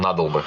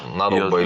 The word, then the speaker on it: надолбы: Не знаю, как ударение ставят. – А никто не знает, на самом деле. Оба варианта, по-моему, нормально. И надолбы, и надолбы.